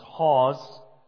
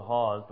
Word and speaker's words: Haweis: A man named Thomas Hawes, very British name in the way it's sounded and also spelt. It's spelled H-A-W-E-I-S. So you look at the name and There's no way you'd come up with Hawes,